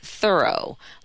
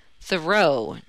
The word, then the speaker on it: Thoreau: Here in Concord, we say Thoreau, like furrow, but most people say Thoreau, J.